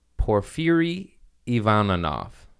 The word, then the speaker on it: Porfiry Ivanov: Porfiry Ivanov. – Perfect pronunciation, by the way.